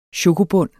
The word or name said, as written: chokobund